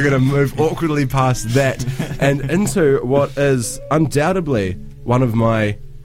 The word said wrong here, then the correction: undoubtedly: I pronounced the B in undoubtedly. Um, undoubtedly, one of my favourite tracks I've heard so far this year.